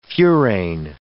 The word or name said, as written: furane